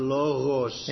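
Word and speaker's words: Logos (λόγος): The noun is the logos. – Logos. But you got to say it with a ch in your mouth. – Logos.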